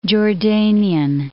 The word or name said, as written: Jordanian